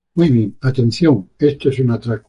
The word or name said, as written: atraco